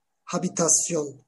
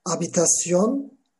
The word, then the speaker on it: habitación: La habitasyon olarak yazılıyor. La habitasyon diye de okunuyor arkadaşlar. Oda. Tek kişilik oda veya iki kişilik oda. Otele gittik, oda istiyoruz. Tek kişilik oda istiyoruz habitación individual.